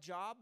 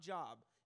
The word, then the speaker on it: Job: It's spelled like Job, but it's not Job. If you say Job, you were wrong, okay?